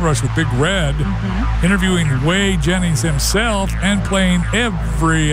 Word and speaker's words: Whey: We're going to play Way or No Way, and it's spelled W-H-E-Y because it's all about Way Jennings, the grandson of Waylon Jennings, who's going to be in concert at the Depot Village in Gainesville on October 22nd.